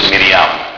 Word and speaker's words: Miriam: Her name in Hebrew is Miriam. So Miriam Nazareth, that's how you would say her name, Miriam of Nazareth, Mary of Nazareth.